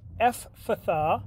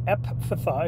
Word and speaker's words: Ephphatha: he uh, looks up to heaven, groans, and says, Ephphatha, which or epphatha, if you want to say it like that, which means be opened.